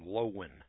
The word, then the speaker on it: Loewen: All right, Lies My Teacher Told Me, a book written by a fellow named L-O-E-W-E-N. Is that the way to pronounce that name? L-O-E-W-E-N. Let's call him Lowen. James W. Lowen. Write that name down. It's L-O-E-W-E-N.